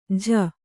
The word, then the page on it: ಝ: jha